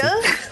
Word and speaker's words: Martin: Você falou em francês, eu achei sensacional. Eu queria que fosse assim a pronúncia. Martin? – Aston Martin. É, Martin. perfeito, cara.